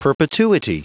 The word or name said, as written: perpetuity